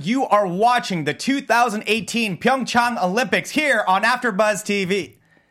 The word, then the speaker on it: PyeongChang: No, see, PyeongChang. No, it's like Rick Hong. It's like Rick Hong, PyeongChang.